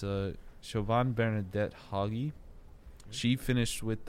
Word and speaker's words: Siobhan: I always forget how to pronounce that name, but that's like a it's like Siobhan something like that. – Yeah, it's Siobhan. It's Siobhan.